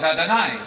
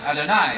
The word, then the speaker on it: Adonai: But they speak of God as Adonai, Adonai, Adonai. You can remember this, it rhymes with El Shaddai.